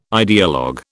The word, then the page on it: ideologue: British English